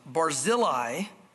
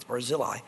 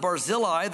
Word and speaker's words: Barzillai: I'm going to say this right. I said it wrong last time. Barzillai. Everyone let me know that. You said it wrong. It's not Barzillai. It's Barzillai. All you biblical scholars in your pronunciation, I stand corrected.